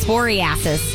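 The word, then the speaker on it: cyclosporiasis: Okay, so the cyclosporasis, is that how you say it? Sporiasis. Sporiasis, yeah. Whatever.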